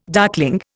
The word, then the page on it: darkling: Indian